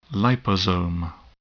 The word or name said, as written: liposome